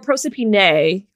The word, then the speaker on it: Proserpina: Proserpina or Proserpine, I think is the other way to pronounce it.